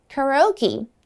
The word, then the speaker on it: karaoke: 哎，不过注意哦，卡拉 OK 它的英文发音截然不同，哎，是这么说的哦，Karaoke Karaoke。